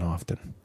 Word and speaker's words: often: There's no hard T in often. It's a silent. It's soft. Silent T.